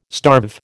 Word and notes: starve: American English

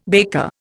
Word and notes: baker: Indian

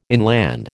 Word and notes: inland: American English

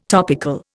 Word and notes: topical: Indian

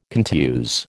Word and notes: contuse: American English

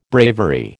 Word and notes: bravery: American English

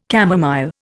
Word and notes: camomile: Indian

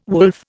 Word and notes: wolf: Indian

